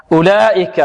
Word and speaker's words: أولئك: أولئك، هنا جاءت كلمة أولئك هذه بارك الله فيكم، فيها واو تكتب ولا تنطق، تكتب ولا تنطق. أولئك هذه التي فوق الواو تسمى ماذا؟ تسمى دارة، دارة، وهذه من علم الضبط، وضعت فوق الواو لتدل على أن الواو تكتب ماذا؟ ولا تنطق.